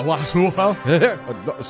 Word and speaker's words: Huatulco: I cannot say Huatulco. – There's a T in there, Tom. – A Huatulco? – So close. T and a C.